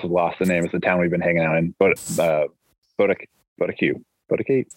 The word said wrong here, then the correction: Boquete: I'm saying it wrong. – Right, anyway, now. No, yeah, it looks like it's a Boquete.